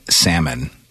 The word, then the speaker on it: salmon: Another thing, when people pronounce the L in salmon.